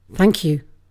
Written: thank you

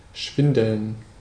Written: schwindeln

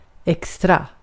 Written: extra

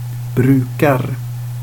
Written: brukar

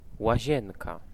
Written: bath

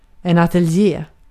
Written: ateljé

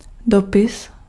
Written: lettre

